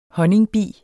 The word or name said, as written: honningbi